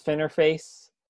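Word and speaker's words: Finerface: S-F-I-N-E-R-F-A-C-E. – Oh, I've been pronouncing it Finer Face. – Well, that's my fault, because my name doesn't have two N's in it. So if it was F-I-N-N, then, you know, but whatever.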